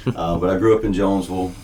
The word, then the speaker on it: Jonesville: Yeah, so as I was saying earlier, I am originally from the big city of Jonesville, South Carolina. I completely just said it incorrectly. It's Jonesville. The S is silent.